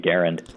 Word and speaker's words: Garand: People look at it on paper and say Garand because it looks like that's how it should be pronounced, but the guy himself and his family pronounced it with the emphasis on the first syllable.